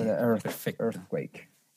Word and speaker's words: earthquake: The Boy Who Predicted Earthquakes. Earthquake. Lo dije bien, Pancho. Earthquake. Earthquake. ah, con la, con la TH. Earthquake. – Earthquake. Perfecto.